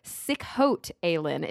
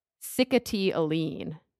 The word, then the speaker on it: Sikhote Alin: Oh, Sikhote Alin is how I thought you pronounced it, but mm-hmm. That's totally not right at all. It has like this weird pronunciation that, oh. Sikhote Alin.